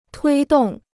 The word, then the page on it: 推动: tuī dòng